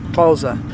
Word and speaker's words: Xhosa: Xhosa.